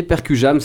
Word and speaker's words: Percujam: Donc, allez checker Percujam, c'est comme ça se prononce. Percu et Jam, tout simplement.